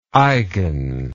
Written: eigen

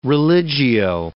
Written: religio-

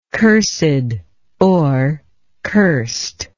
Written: cursedness

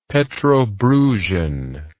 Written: petrobrusian

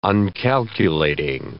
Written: uncalculating

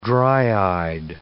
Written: dry-eyed

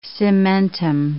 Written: cementum

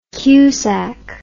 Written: cusack

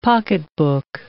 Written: pocketbook

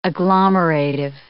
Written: agglomerative

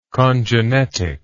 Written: congenetic

